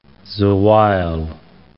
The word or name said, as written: Zewail